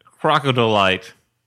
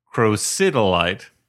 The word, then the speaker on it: crocidolite: There's a movie where there's a moment where David Caruso looks at some stuff and remarks, "Yep, that's 100% crocodolite, pronounced like a crocodile version of dolomite." There are several problems with this, not least of which being the correct pronunciation is crocidolite.